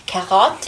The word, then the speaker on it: carotte: it's hard too because the R's in French, when you pronounce an R in French, it's at the back of your throat. Like when you say carrot, carotte, it's at the back, right?